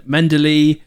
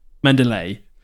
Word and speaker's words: Mendeley: Google said the British pronunciation. Okay, so American pronunciation, Mendeley, British pronunciation, Mendeley.